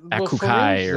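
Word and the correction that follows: açaí: It is pronounced acai. I know because it's Japanese. – Portuguese. – Oh, Portuguese. – Well, Brazilian. – Yeah. Okay. – You know, Brazilian Portuguese.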